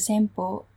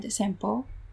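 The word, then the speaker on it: Sempo: So Japanese name has two types of reading style, and one way is a Chune, and the other way is a Senpo, which a bit similar to the Chinese pronunciation, because the Japanese character coming from China.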